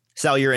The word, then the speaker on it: Salyer: Is that how you say it? Salyer? – Yeah. – Salyer and Dylan Parham being the next couple of guys at 75 and 90 on the consensus board.